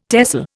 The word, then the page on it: TESOL: Indian